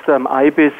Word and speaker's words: IBIS: And is it pronounced IBIS? Talk to me. – Yes, it's pronounced IBIS, like the you know, Egyptian bird of wisdom.